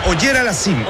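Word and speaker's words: Auger-Aliassime: Auger Aliassime. – Es Auger Aliassime, el canadiense.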